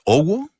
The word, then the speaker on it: OWO: Se llama O-W-O. ¿Cómo la pronuncias? ¿Owo? – Sí, así mismo.